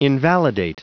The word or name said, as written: invalidate